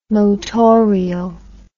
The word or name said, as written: motorial